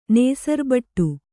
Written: ನೇಸರ್ಬಟ್ಟು

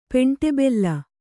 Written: ಪೆಂಟೆಬೆಲ್ಲ

